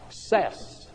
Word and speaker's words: Seiss: It was given by a man whose name is S-E-I-S-S, and it is pronounced Sess.